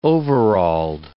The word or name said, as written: overalled